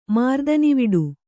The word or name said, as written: ಮಾರ್ದನಿವಿಡು